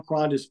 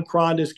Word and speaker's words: Krondes: But uh, if you follow the John Krondes Instagram page, all my social media platforms, they're all the same.